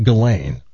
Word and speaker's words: Ghislaine: Ghislaine Maxwell, and that's how you pronounce it, Ghislaine.